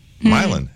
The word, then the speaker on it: Milan: And then I went to school in Ohio, and there was M-I-L-A-N, Ohio. It's Milan. Milan. It's Milan, Ohio.